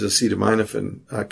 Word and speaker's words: acetaminophen: Well, while I was in Edinburgh, there were also a few of these acetaminophen cases, which, by the way, is the correct way to pronounce that. – Acetaminophen. The Brits converted you. – Well, no, acetaminophen, because it's an amino acid. So that's how acetaminophen is pronounced.